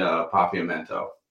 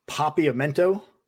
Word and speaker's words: Papiamento: She speaks Dutch, Spanish, English, and Papiamento, which is Aruban. – Papiamento? – Yeah, I think that's the right pronunciation.